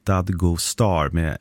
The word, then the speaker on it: Dadgostar: Efternamnet Dadgostar uttalades ungefär Dadgostar med betoning på första och sista stavelsen.